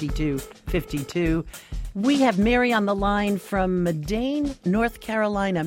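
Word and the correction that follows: Mebane: Mary, am I pronouncing that correctly? – No, actually, it's Medin.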